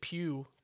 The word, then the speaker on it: Pugh: No, I'm pretty sure she pronounces it Pugh. Because, like, people ask her... – P-U-G-H or something like that. – People ask her how to say her last name, and she goes, Pugh, Pugh, Pugh, Pugh, Pugh.